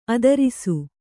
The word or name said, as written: ಆದರ್ಶ